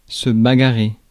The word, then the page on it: bagarrer: [ba.ɡa.ʁe]